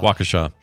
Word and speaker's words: Waukesha: A woman in Waukesha County? – Waukesha.